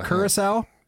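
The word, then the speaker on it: Curaçao: They suggest that it evolved from a pre-existing tequila cocktail called the Tequila Daisy that had tequila, lime, and Coracao, is that how you say it? Or Caraco? How do you say that? Curacao. – Is that right? – That sounds more right.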